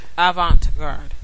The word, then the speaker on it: avant-garde: Avant-garde. How do you say it one more time? – Avant-garde.